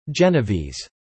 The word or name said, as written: Genovese